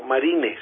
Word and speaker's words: Marine: Y de ahí es el doctor Marín, fue el que, no Marín de Paisa, sino Marine se escribe.